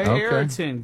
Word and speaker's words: Ariton: A-R-I-T-O-N. – Okay. Ayrton.